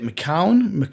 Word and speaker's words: McKeown: McKeown.